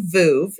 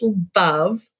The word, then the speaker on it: Veuve: And so it is probably one of the most mispronounced champagnes out there. Most people say Veuve. It's Veuve. It rhymes with love.